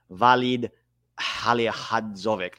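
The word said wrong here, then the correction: Vahid Halilhodžić: Let me get this right because I don't want to mispronounce names. His name is Vahid Halihadzic.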